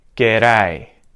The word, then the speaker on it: G'day: Can you hear a hard D sound? G'day, g'day. Am I saying g'day or am I saying g'day, g'day? You've probably worked out what's going on there because we have the vowel sound and then we have the diphthong A after the D. What's happening to the D? It's becoming a D flap or a T flap.